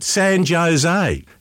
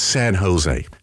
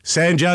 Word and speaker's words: San Jose: He said, "No, mate, San Jose." He said, "Sir, in the United States, we sort of pronounce our J's like an H, so it's San Jose. San Jose.